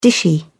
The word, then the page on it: dishy: British English